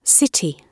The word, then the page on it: city: British English